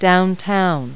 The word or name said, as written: downtown